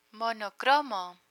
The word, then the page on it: monocromo: Spanish